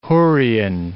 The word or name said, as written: Hurrian